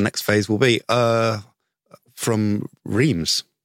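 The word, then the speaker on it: Reims: But this is Reams. It's easy. – So it's much easier to pronounce.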